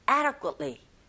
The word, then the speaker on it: adequately: 이렇게 발음하셔야 되는데, 첫 번째 강세를 꽝 주시고, ada, 여기는 d 사운드가 모음 사이에서 르처럼 들려서 ada, ada. 그리고 adequately가 아니라 역시 t 사운드가 거의 안 들리죠. 그래서 여러분들이 들으셨던 원어민의 소리가 adequately, adequately 이런 식으로 발음을 들리게 되고 이렇게 하시면 됩니다.